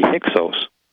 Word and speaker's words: Hyksos: But they were also called by the Greek historians Hyksos. And also, or better, another way of pronouncing it would be Hyksos.